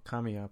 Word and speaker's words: Kamiya: You said it right the first time. – Oh, I did. Hideki Kamiya? – Well, Kamiya, but...